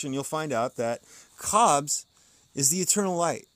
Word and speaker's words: Khabs: It's spelled K-H-A-B-S, Cobs. The Cobs is in the coup, K-H-U, not the coup in the Cobs.